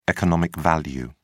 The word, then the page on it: economic value: British English